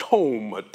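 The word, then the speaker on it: tome (תם): Interesting word in the Hebrew for integrity is the word tome, T O M, long O tome.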